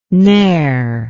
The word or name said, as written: neer